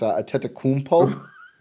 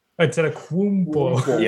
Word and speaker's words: Antetokounmpo: Also, you pronounce Giannis's last name wrong. It's Antetokounmpo. – Antetokounmpo. – Yeah, it's the W. – You forgot the W.